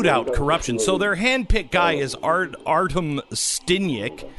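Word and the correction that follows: Sytnyk: How do I say it? What's his name? Stinyak? – Sitnik. – Sitnik. Okay, Sitnik. Whatever.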